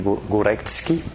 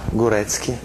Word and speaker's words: Górecki: Górecki, así se dice, maestro, ay, mira, Górecki.